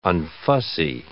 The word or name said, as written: unfussy